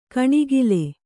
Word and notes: ಕಣಗಲು: kaṇagalu

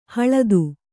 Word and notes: ಹಳತು: haḷatu

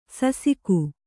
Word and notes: ಸಸಿಕು: sasiku

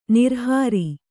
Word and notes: ನಿರಾಹಾರ: kannada

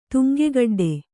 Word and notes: ತುಂಗಗಡ್ಡೆ: Kannada

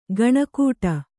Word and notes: ಗಣಕೂಟ: gaṇa kūṭa

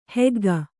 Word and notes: ಹಗ್ಗಿ: haggi; Kannada

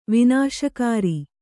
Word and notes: ವಿನಾಶಕರ: kannada; vināśakara

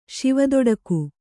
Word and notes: ಶಿವದೊಡಕು: kannada

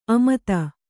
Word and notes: ಅಮತ: amata

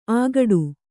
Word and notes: ಅಗಟು: Kannada

